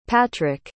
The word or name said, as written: patrick